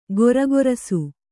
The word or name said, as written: ಘುರುಘುರಿಸು